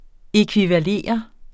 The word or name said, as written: ækvivalere